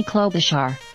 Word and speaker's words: Klobuchar: Amy Klobuchar. – Klobuchar. I was close.